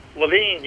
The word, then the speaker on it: Levine: Do you pronounce your last name Levine or Levin or Levine? – Levine, you got it.